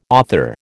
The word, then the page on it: author: American English